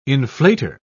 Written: inflator

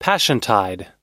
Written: passiontide

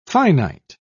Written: finite

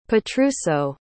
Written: petruso

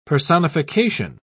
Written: personification

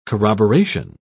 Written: corroboration

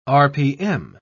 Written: rpm

